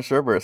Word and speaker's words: sherbet: No, you probably are right, but I grew up calling it sherbet, so it's called sherbet. – Everybody did, but everybody's retarded because there's no second R in it.